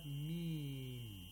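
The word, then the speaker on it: ميم: And essentially, what you're supposed to do is that you have to pronounce the names of these letters. We have ha. Okay? – Yes. – So we have to say ha. Then we have meem. Okay, so yes. – We pronounce the name of the letter.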